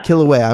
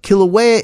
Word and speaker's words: Kilauea: Kilauea. – I'm sorry. Kilauea